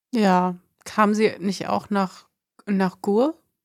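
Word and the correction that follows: Gurs: Nach Gürs, ja, genau. Gürs. – Oh, ich habe es falsch ausgesprochen.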